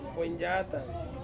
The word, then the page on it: boindyatang: Ansus